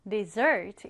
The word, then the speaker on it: dessert: 발음할 때는 강세를 뒤에 두어서 dessert 이렇게 읽습니다.